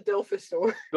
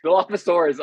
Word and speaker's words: Dilophosaurus: The ones that spit were called the Dilophosaur. – Dilophosaurus.